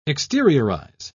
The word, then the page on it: exteriorize: British English